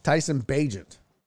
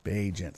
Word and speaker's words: Bagent: Tyson Bagent. Bagent.